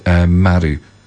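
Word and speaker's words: maru (丸): A circle in Japanese is pronounced maru.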